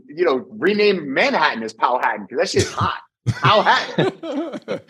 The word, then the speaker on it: Powhatan: Yeah, so Powhatan. I'm trying to figure out how to properly pronounce it. That's where I'm at right now. – It's Powhatan, man. You got Manhattan and Powhatan.